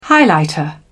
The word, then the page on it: highlighter: British English